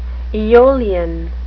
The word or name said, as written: eolian